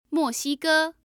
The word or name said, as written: moxige